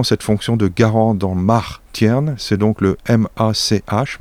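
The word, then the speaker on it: march'h: Une troisième possibilité, qui, elle, serait plus fautive d'après les linguistes, c'est Mar, qui se prononce en breton Mar, qui est M-A-R-C apostrophe H,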